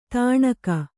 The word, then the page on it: ತಣುಕು: taṇuku